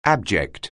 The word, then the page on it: abject: anglais